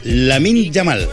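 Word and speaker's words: Lamine Yamal: Ahí tenemos la canción esta que está de moda, que yo antes lo dije mal, que mi hija le dio vergüenza, se llama Lamin Yamal. Lamin, con L. Lamin Yamal.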